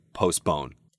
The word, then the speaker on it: postpone: Really, you don't need to worry about the T. Most people will just say postpone.